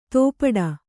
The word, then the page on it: ತೋಪಡ: tōpaḍa